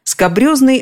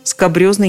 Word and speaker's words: скабрёзный: Вот выясним сейчас, откуда оно взялось, и легче будет запомнить, как оно произносится скабрезный от французского «скабрё» – «скабрёз». Это значит, во-первых, опасный грубый. Во-вторых, собственно, скабрёзный непристойный.